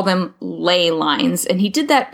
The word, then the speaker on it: ley: We spell it ley, L-E-Y. – Mm-hmm. – But the maps showed L-A-Y, L-E-A, L-E-E, L-E-I-G-H.